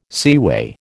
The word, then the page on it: seaway: American English